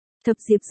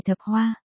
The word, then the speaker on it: Thập Diệp: Thập Diệp dừng cước bộ lại, quay đầu nói. Ta là Thập Diệp, không phải Thập Hoa.